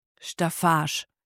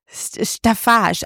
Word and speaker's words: Staffage: Schau, wie spricht man das aus? – Staffage. – Staffage, aber wann... Sagt ihr das so? Staffage? – Staffage, ja. Ist ein altes Wort.